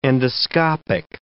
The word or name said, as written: endoscopic